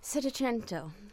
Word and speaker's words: Settecento: Settecento.